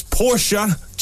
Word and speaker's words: Porsche: See, it was a Porsche joke we just had. – Thanks for the call. Thanks for clearing that up. See, it's Porsche. just like the song.